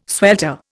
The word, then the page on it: swelter: Indian